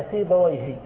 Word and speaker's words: سيبويه: نعم، لكن لو أخذنا مثلا في بويه فنعرف أنه اسم مبني على الكسر، في كل اللغة يطلق وينطق هكذا في بويه، سواء جاء في محل رفع فاعل، أو جاء مفعولا به في محل نصب، أو جاء مسبوقا بحرف جر، لا تنطقه العرب إلا بهذا اللفظ في بويه.